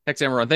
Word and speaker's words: Hexameron: On the 6 days Hexameron. – Of creation, Hexameron.